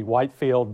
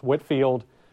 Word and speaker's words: Whitefield: Um, the most famous preacher at the time, for sure, is George Whitefield. Um, and I know the way it's spelled, it looks like it would be Whitefield, but it, I'm on good authority, I'm told it was pronounced Whitfield.